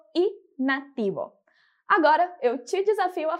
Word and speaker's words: nativo: E para fechar os nossos sons de hoje, a gente tem a palavrinha nativo, que significa nativo, e que a gente vai pronunciar como nativo, nativo.